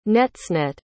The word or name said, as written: netsenet